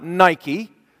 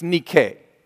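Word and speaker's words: Nike (Νίκη): It is not Nike. Stop them and say, no, it's not Nike, it's Nike.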